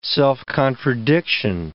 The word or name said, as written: self-contradiction